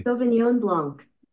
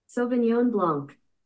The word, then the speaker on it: Sauvignon Blanc: Sauvignon Blanc. – Sauvignon Blanc. – Yeah, there's a completely separate syllable in there that you're not saying.